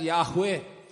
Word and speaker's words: Yahweh: Then we find David using the word Jehovah. It's better pronounced as Yahweh.